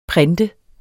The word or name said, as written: printer